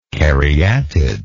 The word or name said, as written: caryatidal